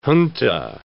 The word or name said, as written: junta